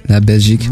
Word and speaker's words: La Belgique: I hope you have a lovely day in La Belgique, right? – Yeah. That's the correct way to say it. La Belgique.